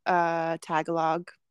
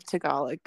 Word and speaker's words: Tagalog: Dude, speaking of things that I never knew how to pronounce, my whole life I pronounced Tagalog like that instead of Tagalog.